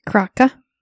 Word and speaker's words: krake: The name Kraken is derived from the Norwegian word Kraka? I don't know how to pronounce it. K-R-A-K-E. – Kraka. – Okay, like that.